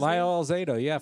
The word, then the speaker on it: Lyle Alzado: Lyle Alzado, from Yankton College.